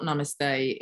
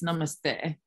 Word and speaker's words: namaste: I think it was really, I mean, firstly, everybody pronounces it wrong because it's not namaste, it's namaste, and it's a Hindu thing.